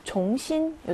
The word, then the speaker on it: 重新: 얘는 무거울 중자잖아요. 그래서 쫑이라고 읽어야 왔는데 여기서는 쫑으로 읽지 않고 종으로 읽습니다. 네, 다시 라는 의미로 활용이 될 때는 종하고 이성으로 읽어주셔야 돼요. 종신 요 단어 따로 좀 기억하시고요.